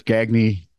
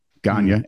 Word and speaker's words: Gagne: And then that's when we figured out that G-A-G-N-E is pronounced a lot of different ways. – Yes. – There's Gagne, Gagne, and Gagne.